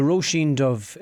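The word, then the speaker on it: Róisín Dubh: So he booked me in and over at the Roisin Dub. Is that how you say it? Roisin Dub? – So it's the Roisin Dove. It translates as the Black Rose.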